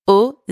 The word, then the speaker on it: aux: If the country is plural, then you're going to use the preposition au with a silent X at the end. For example, j'habite aux États-Unis.